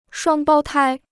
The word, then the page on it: 双胞胎: shuāng bāo tāi